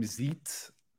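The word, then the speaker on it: Xeets: Well, they said on their official thing now, it's like X-E-E-T-S. I don't know how else you would pronounce that.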